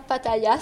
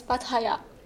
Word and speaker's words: Pattaya: Juste pour info, ça se prononce pas Pattaya, ça se prononce Pattaya.